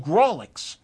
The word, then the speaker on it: Grawlix: And you can get a copy, an ebook version of what the Gravlax is that. I said it wrong, didn't I? You know what I think? It's a Grawlix.